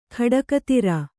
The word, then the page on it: ಖಡಕತಿರಾ: khaḍakatirā